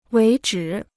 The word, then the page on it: 为止: wéi zhǐ